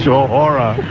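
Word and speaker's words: Shohreh: Shahora. Is that the real pronunciation of it? – Yes, it is.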